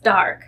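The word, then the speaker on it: D'Arque: And I was like, it's pronounced dark.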